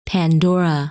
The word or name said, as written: Pandora